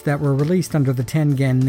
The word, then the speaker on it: Tengen: The correct pronunciation for the name of the company was Tengen, with a hard G. The name, as I mentioned, is a term derived from the Japanese tile game Go, and in Japanese, with very few exceptions, a G is always pronounced with a G sound, not a J sound.